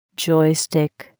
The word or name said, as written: joystick